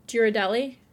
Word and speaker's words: Ghirardelli: So I had Home Depot gift cards, and then it had these cute little Ghirardelli. Is that how you say it? – Mm-hmm.